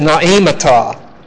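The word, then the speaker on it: naemata (νοήματα): If you want to look the word up, that word is naemata. Naemata. N-O-E, long E, M-A-T-A.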